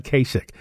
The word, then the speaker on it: Kasich: Christie, Rubio, and Ohio Governor John Kasich all said that, and it's just taken me this long to realize that that's how the man's name is pronounced, John Kasich, all said that the U.S.